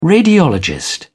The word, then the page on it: radiologist: British English